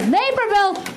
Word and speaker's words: Naperville: Naperville? – Naperville. Brody Stevens, let's hear from Brody Stevens, just correcting his pronunciation on Chicago suburbs.